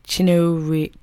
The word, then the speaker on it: Chinouriri: Rachel Chinnariri. Did I say that right? Chinnariri. C-H-I-N-O-U-R-I-R-I.